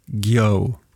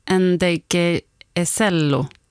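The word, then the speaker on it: Ndegeocello: Michelle Ngeyoye. – Nej, Ngeyocello. Ngeyocello, ja.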